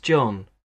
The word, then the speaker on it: John: My first name's John. J-O-H-N. Yes, J-O-H-N. And my surname's Murphy. M-U-R-P-H-Y.